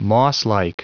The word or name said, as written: mosslike